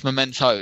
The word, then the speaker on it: Memento: You know, not to say that something like Momentum is a great example. – Momentum. Because that you mean tells Memento. – It's Memento, isn't it? I always mispronounce it.